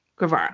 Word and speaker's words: Guevara: Guevara.